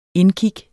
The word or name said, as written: indkig